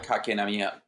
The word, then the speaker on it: Kotkaniemi: Kotkaniemi. I'm I'm probably butchering the pronunciation. No, you're you're, you're good. Kotkaniemi.